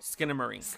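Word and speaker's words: Skinamarink: Skinamar Inc. Am I saying that right? – Skinamar Inc. – Skinamar Inc. – Yeah, just like the song.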